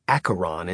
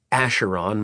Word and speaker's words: Acheron: He actually pronounced his name with the full Greek accent so that it came out as Acheron instead of the Acheron most non-Greek people used when referring to him.